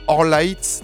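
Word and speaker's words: Orlaith: Alors Molly McGinn et alors Orlight Forsis. Alors son prénom, ça s'écrit O-R-L-A avec un accent I-T-H. Le nom de famille, c'est F-O-R-S-Y-T-H-E, donc Forsis.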